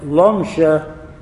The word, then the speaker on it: Lomja: You notice I said Lomja even though it's hard to pronounce the Lamed, because if you're from Lomja, you can't pronounce an L.